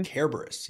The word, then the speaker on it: Kerberos: Kerberos, yes. Kerberos. In Latin, Kerberos. There's no soft C.